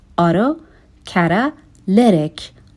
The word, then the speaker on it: autocatalytic: این کلمه تلفظ شاید براتون سخت باشه. میتونیم بخش بخش بخونیمش. Autocatalytic.